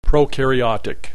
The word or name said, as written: prokaryotic